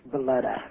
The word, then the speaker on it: Belete: It's Beletta. – Beletta, okay. Well, it's spelled is it spelled B-E-L-E-T-E? – Yes.